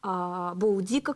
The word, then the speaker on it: Боудика: Особо интересно, конечно, потому что восставших возглавила женщина Боудика, как сейчас принято ее произносить, или Боадицея, как писал Тацит.